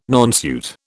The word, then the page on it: nonsuit: British English